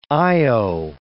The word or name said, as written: io